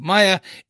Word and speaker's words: Maya: I think, so basically where this comes from is, obviously her name is pronounced Maya in the show. I have a sister called Mia. And there is also, when you would read it M-A-Y-A, in Ireland, a lot of people would pronounce that Maya.